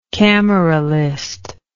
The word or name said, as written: cameralist